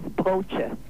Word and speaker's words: broche: The pronunciation is broche. – Absolutely. You have won.